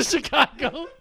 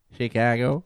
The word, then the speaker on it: Chicago: You eat the A a little bit. – Chicago. – Chicago.